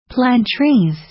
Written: plant trees

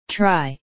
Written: try

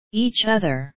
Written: each other

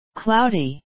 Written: cloudy

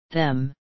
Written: them

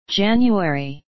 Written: january

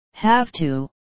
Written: have to

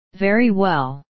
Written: very well

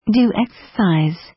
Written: do exercise

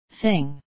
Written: thing

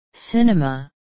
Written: cinema

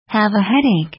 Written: have a headache

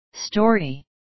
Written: story

